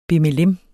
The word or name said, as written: bimmelim